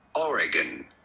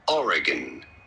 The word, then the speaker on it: Oregon: Oregon. You do want to stress on the first syllable, the R syllable. Oregon.